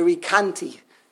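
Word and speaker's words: Recanati: So I've always heard in the yeshivish world, the Rikanti. And here he pronounces it over here, the Rikanati. Rikanati.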